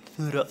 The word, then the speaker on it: Thoreau: Estoy ahí y empecé a leer también una cosa que es de Churo, no sé cómo se pronuncia, Churo. – Churos. ¿El Churos? – El Churos. – Henry David Thoreau.